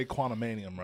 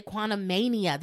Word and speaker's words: Quantumania: I'm pretty sure when people talk about it, they say Quantumanium, right? – They say Quantumania. There's no extra.